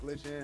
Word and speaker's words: Glxtchn: Just Glxtchn? How you spell it? – GlxtchN. Not gluten, by the way.